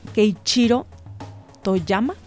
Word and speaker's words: Keiichiro Toyama: Y bueno, también cabe mencionar que cuando se lanzó este videojuego, el creador Keiichiro Toyama,